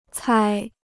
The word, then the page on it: 猜: cāi